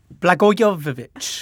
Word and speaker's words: Blagojevich: Blagojevich.